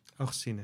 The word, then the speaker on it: Augustine: Dat is Augustine, zeg ik dat goed? – Augustine.